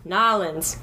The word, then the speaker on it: New Orleans: Okay, so the proper way to say it is, Nolans.